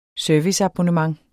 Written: serviceabonnement

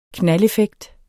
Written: knaldeffekt

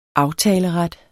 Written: aftaleret

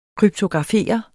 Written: kryptografere

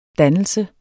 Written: dannelse

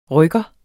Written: rykker

